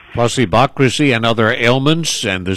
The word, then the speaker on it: Placebocracy: It's placebocracy.